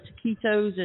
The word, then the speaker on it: taquitos: You know, you mainly got the taquitos and, did I say that right? Taquitos? Yeah.